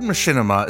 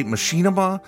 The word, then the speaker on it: machinima: The word machinima is a portmanteau of machine and cinema. Some people prefer to say machinima. I'm using machinima. Both pronunciations are totally fine.